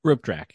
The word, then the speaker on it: Rope Draak: Rope Draak, yeah.